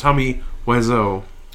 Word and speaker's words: Tommy Wiseau: I believe it's pronounced Tommy Wiseau.